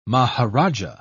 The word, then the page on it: maharajah: British English